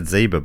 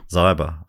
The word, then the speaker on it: Zyber: I would have said Zyba, but luckily Donna put in brackets, pronounced like cyber. – Donna Zyber.